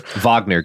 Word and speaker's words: Wagner: Wagner, Garrison. It's important to pronounce things correctly.